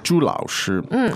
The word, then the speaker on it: 朱老师: 朱老师. And it sounds uncommonly like. – It sounds exactly like. – The word for pigs. – Pigs, which is also pronounced. – 猪. – Same tone, same syllable.